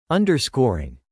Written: underscoring

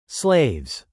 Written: slaves

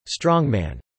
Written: strongman

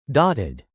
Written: dotted

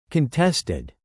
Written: contested

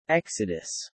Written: exodus

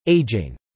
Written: ageing

